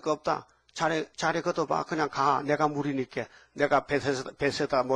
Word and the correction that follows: 베데스다: 물이니까. 말도 잘한데. 베데스다. 한번 해봐요. 베데스다. 아, 여러분 참 발음이 좋네.